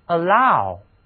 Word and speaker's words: allow: 这里的字母 ow 读音要变化读作 ow allow